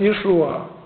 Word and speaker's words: Иешуа: Оно произносилось Иешуа, а не Иисус или Иисус.